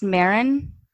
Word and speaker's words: Maren: So is her name pronounced Marin? – Yep.